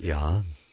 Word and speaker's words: Jah: Of course, the J came 500 years ago, so it in the modern scriptures, including King James, it says Jah, J-A-H. But we pronounce it Yah.